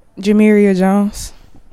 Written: Jamiria Jones

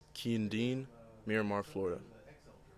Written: Keeyen Deane

